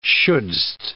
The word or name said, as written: shouldst